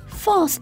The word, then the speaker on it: false: false 中的 al 发的是 all 的音。 false。